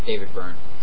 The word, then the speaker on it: Byrne: David Byrne.